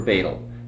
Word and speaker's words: Bethel: Some congregations collect stamps for Betel. It's spelled Bethel. Okay? And they pronounce it Betel.